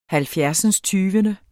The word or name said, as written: halvfjerdsindstyvende